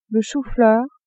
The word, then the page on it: chou-fleur: female